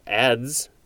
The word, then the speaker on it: adze: And that is from dyein, D-I-E-I-N, D-Y-E-I-N, which means to enter. Next is A-D-Z-E. I guess it's just pronounced ads. It's also spelled A-D-Z.